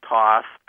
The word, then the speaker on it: Thoth: a fellow named Thoth. Some people pronounce it Thoth.